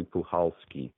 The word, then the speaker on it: Puchalski: I said uh, Puchalski. Is it Puchalski, Puchalski? But I, I talked to some uh, Polish uh, expert in, in, in names, and he said the C is silent. It's actually Puchalski so now now I'm pronouncing it right.